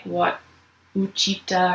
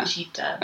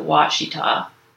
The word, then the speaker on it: Ouachita: It's right outside of the Ouachita National Forest, which That's spelled O-U-C-H-I-T-A. And when I looked up how to pronounce it online, all of the tapes were in a Southern accent. So they were all like, Washita.